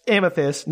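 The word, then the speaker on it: Amythis: And that's Amethyst, not Amethyst. To be clear. – Is it spelled the same just without the T? – It's A-M-Y-T-H-I-S.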